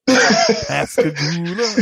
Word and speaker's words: Pascagoula: And you guys said it right. – Pascagoula.